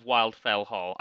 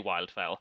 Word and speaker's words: Wildfell Hall: But the one I loved is The Tenant of Wildfell Hall. I always pronounce it Wildfell Hall. There's no reason for that in the English language. It's clearly Wildfell.